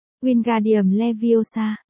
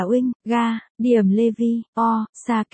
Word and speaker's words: Wingardium Leviosa: Hermione cau nhau, bạn sai rồi, phải đọc là Wingardium Leviosa kéo dài chữ ga, một cách nhẹ nhàng và duyên dáng.